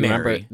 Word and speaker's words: Mari: It is spelled M-A-R-I. – That's why. It was because they were getting fancy, and yeah. It's spelled M-A-R-I, and I was looking at it. I wasn't getting fancy intentionally. But Mary.